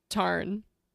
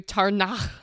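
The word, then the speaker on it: Tairn: I think we've settled on Tarn. Tarn. Unless you want to do Tarnach every single time you say the name.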